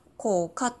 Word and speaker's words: 甲賀: で、あの、甲賀市も、甲賀市もそうなんです。これ濁っちゃダメなんですよね。あの亀の甲羅の甲に、えっと、年賀状の「が」って書いて「甲が」「甲賀」って読むんですけど KOKA ですね普通に読むと「甲賀」